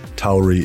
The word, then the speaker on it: Tauri: Personally, when I'm using it in a sentence, I would say Tauri is this, that, or the other thing. So for me, it's Tauri.